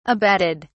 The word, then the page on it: abetted: British English